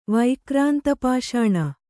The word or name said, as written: ವೈಕ್ರಾಂತಪಾಷಾಣ